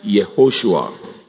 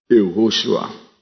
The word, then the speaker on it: Yehoshua: The name we call Joshua in Hebrew is Yehoshua. Yehoshua. Hallelujah.